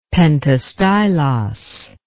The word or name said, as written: pentastylos